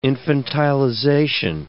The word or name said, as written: infantilization